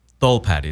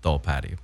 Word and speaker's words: Tholpady: Tholpady. It's supposed to be pronounced Tholpady.